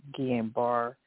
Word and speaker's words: Guillain-Barré: People got that Guillain-Barré.